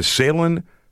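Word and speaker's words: Sailun: Yeah, you know, and while we're talking about this, you can go to Salen.ca. – Mm-hmm. Salen Tires.ca. – Salen Tires.ca. And it's spelled S-A-I-L-U-N. T-I-R-E-S.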